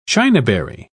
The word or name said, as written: chinaberry